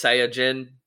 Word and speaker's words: Saiyajin: And in the Japanese language, you would say it's Saiyajin, but it's pronounced, or Saiyajin. But in the fun animation English dubs, it's pronounced Saiyan.